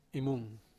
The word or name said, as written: mung